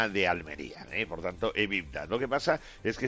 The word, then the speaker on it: EBITDA: EBITDA, sí.